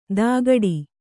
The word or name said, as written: ದಗಡಿ